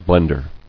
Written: Blender

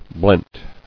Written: Blent

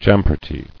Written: Champerty

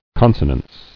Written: Consonance

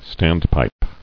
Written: Standpipe

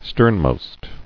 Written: Sternmost

